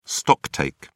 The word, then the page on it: stocktake: British English